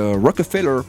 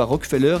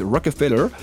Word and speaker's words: Rockefeller: Je dis exprès Rockefeller parce que c'est comme ça qu'ils le prononcent. Eux ne disent pas Rockefeller, ils disent Rockefeller.